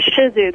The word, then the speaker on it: Shih Tzu: A Shih Tzu. The T is silent. It's not Shih Tzu. It's Shih Tzu.